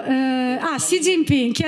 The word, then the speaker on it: Xi Jinping: no, si dice, i, i, giornalisti di solito dicono Xi Jinping, che proprio no, perché la G finale non si legge, quindi è Xi Jinping, mi raccomando.